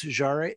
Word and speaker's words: Jarre: They also said that the other big influences for the soundtrack were Max Steiner, Bernard Herrmann, John Carpenter, Vangelis, and Maurice Jarre. Would you pronounce it Jarre? Maurice Jarre or Maurice Jarre? – Jar.